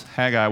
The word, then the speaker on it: Haggai: Haggai, for your information, is not pronounced like that. It's minus an H, actually. Their H was silent, depending on where you find it in the word, and often when it's the beginning of the word. And so pronounce Haggai without the H, and what does it sound like? Sounds like an Aggie, right?